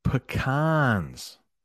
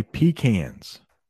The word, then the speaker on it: pecan: If you're in the northern part of the United States, you will probably hear this nut pronounced pecan. If you go to the South, Alabama, Florida, Georgia, those states, you will hear it pecan, pecan, pecan, pecan.